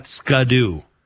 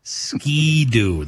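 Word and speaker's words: Ski-Doo: We're talking KTM, Husqvarna, Polaris, Can Am, BMW, Triumph, Ducati, Yamaha, Honda, and Ski Doo, not Skadoo. Ski Doo. That's how you say it.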